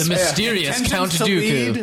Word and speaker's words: Dooku: Oh, it's Count Dooku,